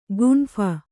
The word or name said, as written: ಗುಂಫನ